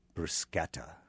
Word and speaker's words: bruschetta: There are two ways. It's either bruschetta or bruschetta. Mm-hmm. And the correct way is bruschetta. – Oh, with a hard C. – Yeah, give it the K sound instead of the CH.